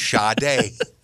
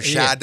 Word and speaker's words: Sade: No, her name was Sharday. We could all say that. Let's say it together. Sade.